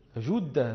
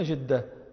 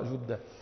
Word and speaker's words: جدة: وفي مثل ما بين مكة وجدة، وجدة أيضا مدينة قديمة، والفصيح أن يقول الناس جدة بضم الجيم، لا يقولون جدة، نحن نقول جدة، وأهل جدة يقولون جدة فيكسرونها، وكل لحن، والصواب كما نطق الموطأ جدة.